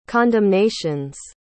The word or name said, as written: condemnations